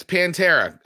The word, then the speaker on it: Pantera: I feel like I'm saying that name wrong, but you haven't corrected me. – No, it's Pantera.